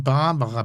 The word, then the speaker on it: Barbara: Ein tolles Buch von Barbara Becker. – Das kann sein. – Also mit irgendeiner Ex-Frau von... – Betonung auf Bar.